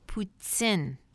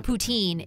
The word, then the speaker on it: poutine: This is episode 104, Canned Poutine. And it's not poutine, it's poutine.